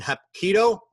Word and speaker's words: Hapkido: He recently earned a second degree black belt in Hapkido.